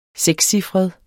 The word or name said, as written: sekscifret